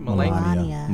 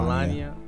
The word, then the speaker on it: Melania: Um, Let's pronounce her name correctly. She's the first lady. – What's her name? Melania. Melania. Melania.